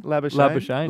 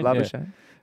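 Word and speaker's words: Labuschagne: LaBouchaine. – LaBouchaine.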